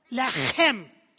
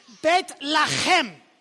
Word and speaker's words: Bethlehem: But we kept following the star and we went to Bethlehem. That's how you say it. No. Lechem. Like you have something stuck in your throat. Bethlehem. That's how we say it in our language.